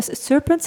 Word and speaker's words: Serpent: Kruip je even achter Heb je ook dat uh, Serpent, zeg ik dat goed? – Ja.